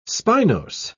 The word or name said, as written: spinose